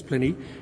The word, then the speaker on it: Pliny: And one example of this is a man called, I don't know if you pronounce it Pliny or Pliny, I think it's Pliny.